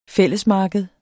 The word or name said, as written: fællesmarked